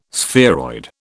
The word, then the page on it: spheroid: British English